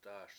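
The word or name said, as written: tá:š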